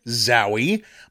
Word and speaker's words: Zowie: My name is Richard Zowie.